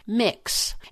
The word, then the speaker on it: Mx: wanted to be called MX, pronounced Mix, and she wanted her pronouns to be they, them, and their instead of she or her.